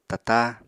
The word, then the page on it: tatá: apatani